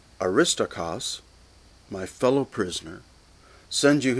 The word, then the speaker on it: Aristarchus: So first, Aristarchus. That's technically the way to pronounce that name, although like Tychicus, it's like Tychicus, it's easier to pronounce it the way it looks, which is Aristarchus.